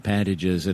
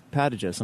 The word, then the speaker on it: Pantages: I love the way you pronounce that, Pantages. I'm going to start doing that. I've heard it pronounced in L.A. – Pantages? – Pantages, yeah, but your way is better.